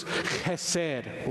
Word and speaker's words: chesed: This word in the Hebrew is chesed, okay? You can spell it C-H-E-S-E-D or H-E-S-E-D, chesed.